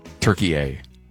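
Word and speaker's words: Türkiye: So tomorrow, Team USA will be back in action against Turkey A. Did I say that right, Alex? – Yeah, you got it.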